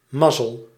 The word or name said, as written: fortune